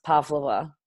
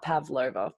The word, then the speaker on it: Pavlova: It's actually named after a Russian ballerina and her last name is pronounced Pavlova, not Pavlova.